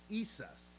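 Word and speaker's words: Iesus: Well, Jerome, when he was translating the name of Yeshua into Latin, he translated it I-E-S-U-S. I-E-S-U-S. And I'm going to try to revisit my Latin for a minute. And it's pronounced Isus.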